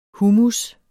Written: hummus

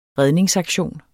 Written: redningsaktion

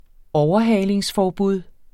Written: overhalingsforbud

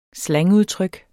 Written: slangudtryk

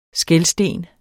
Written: skelsten

